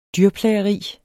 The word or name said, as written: dyrplageri